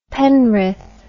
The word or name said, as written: penrith